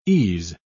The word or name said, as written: ease